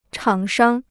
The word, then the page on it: 厂商: chǎng shāng